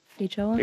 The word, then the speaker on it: feijoa: Yeah, someone calling in about their feijoa plant, if I... Am I pronouncing that correctly? Am I getting... – Yes, that's good. – Feijoa? – Yep.